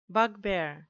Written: bugbear

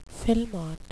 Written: filemot

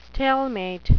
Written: stalemate

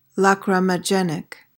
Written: lachrymogenic

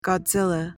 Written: godzilla

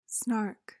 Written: snark

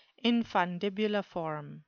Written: infundibuliform

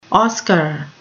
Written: oscar

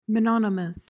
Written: mononymous

